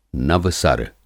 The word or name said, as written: नवसर